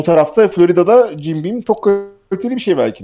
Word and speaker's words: Jim Beam: Nasıl okunuyor? Jim Beam mi? – Jim Beam.